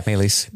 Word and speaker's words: Maelys: Maelys.